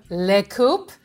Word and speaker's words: Lé Koopé: We are so happy to be joined today by Musette Gonzalez from Le Koopé.